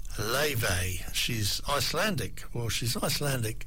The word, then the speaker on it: Laufey: Spell her name Laufey, L-A-U-F-E-Y, if you're looking for it. Check on the internet, it's well worth a look. Leve is the way she pronounces it.